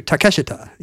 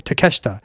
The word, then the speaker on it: Takeshita: But it was really closer to Takeshita, you know, right? – To the degree that you would almost swallow one of the syllables. Like anchor people would often say Takeshita.